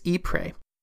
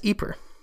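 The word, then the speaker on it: Ieper: So I have been pronouncing the name as Ypres, which is the French name for the city. But the city is actually located in the Flemish region of Belgium, and I am told they pronounce it as Ypres. and it is spelled quite differently.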